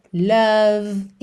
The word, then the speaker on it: love: Gave, more, love, inside, have. Ou seja, essas letras E não são pronunciadas, ok? Então você sempre para na consoante anterior.